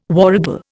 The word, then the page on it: warragal: Indian